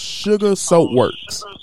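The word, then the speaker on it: Shugar Soap Works: It is called Sugar Soap Works. – Sugar Soap Works? – Sugar. It's spelled S-H-U-G-A-R. – Oh, like sugar, like black sugar.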